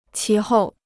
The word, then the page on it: 其后: qí hòu